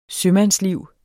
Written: sømandsliv

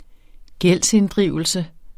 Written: gældsinddrivelse

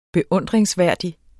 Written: beundringsværdig

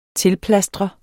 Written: tilplastre

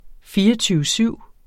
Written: syv

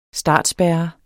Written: startspærre

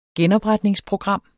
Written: genopretningsprogram

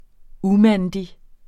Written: umandig

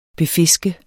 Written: befiske

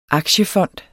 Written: aktiefond

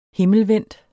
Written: himmelvendt

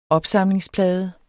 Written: opsamlingsplade

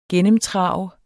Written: GENNEMTRAVET

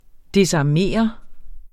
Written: desarmere

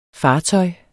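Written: Fartøjer

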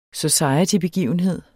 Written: societybegivenhed

